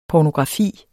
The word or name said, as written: pornografi